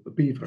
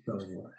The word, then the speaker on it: Belvoir: Well, my father had a pick-your-own-fruit farm here at Belvoir, or Beaver, as we locally pronounce it, but everyone says Belvoir.